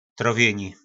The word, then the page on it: trovjyni: po nasimu